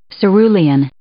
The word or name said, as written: cerulean